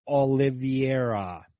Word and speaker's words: Oliveira: That was the title fight against the champ, Charles Oliveira, actually is how you say it.